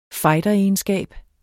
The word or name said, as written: fighter